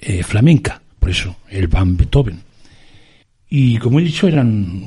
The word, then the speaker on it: Beethoven: Beethoven creo que es como lo pronuncian los alemanes, pero nosotros lo vamos a pronunciar siempre a la española. Es decir, le vamos a llamar Beethoven. Beethoven,